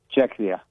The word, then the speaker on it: Czechia: Czechia, yeah.